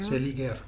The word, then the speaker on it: Seliger: Een verhaal over kamp Seliger, zeg ik dat goed? – Hmm. – Seliger? – Seliger. Dat is een soort een jeugdindoctrinatiekamp voor, voor de nieuwe Russen.